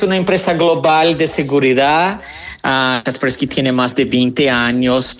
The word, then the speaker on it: Kaspersky: Kaspersky. Exactamente, ahí está bien, muy bien pronunciado.